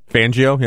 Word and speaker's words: Fangio: Fangio, yeah.